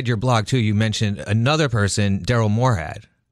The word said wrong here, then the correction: Morehart: Morehead, and he How do you say his last name? – Morehart. – Morehart.